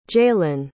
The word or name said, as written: jalyn